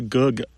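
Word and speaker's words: goog: Full is a gug.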